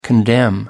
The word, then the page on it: condemn: American English